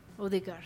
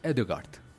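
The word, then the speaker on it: Ødegaard: Y hay un jugador que tiene el apellido Odegaard. – Odegaard. – Pero es Edegaard.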